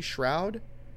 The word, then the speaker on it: Shroud: Shroud, did I pronounce that mm-hmm. correctly? Shroud. Shroud. – So, yep.